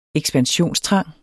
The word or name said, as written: ekspansionstrang